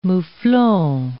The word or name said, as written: mouflon